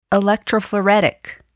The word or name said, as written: electrophoretic